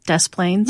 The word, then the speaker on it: Des Plaines: Des Plaines? – Yes.